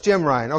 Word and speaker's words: Ryun: It's Jim Ryan. Okay. How do you spell it? Man, Jim gets an A. His name is spelled R-Y-U-N.